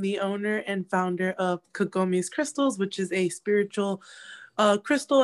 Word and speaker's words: Kagomes Krystals: Kagomes Krystals, yep.